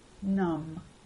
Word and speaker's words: numb: B is silent after M at the end of some words. It stays silent even if you add a suffix. Numb.